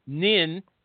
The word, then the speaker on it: Ninh: For our good friend, Nin, N-I-N-H, with the unnecessary H, Nintran, okay?